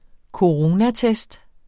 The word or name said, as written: coronatest